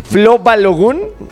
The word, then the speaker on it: Flo Balogun: Con el tema de Flo Balogun.